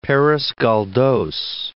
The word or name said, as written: Perez Galdos